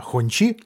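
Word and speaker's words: Hongqi: Ну, коли речь идет за роскошь, то красное знамя, Хончи, я правильно сказал? – Правильно, но надо еще поработать над произношением.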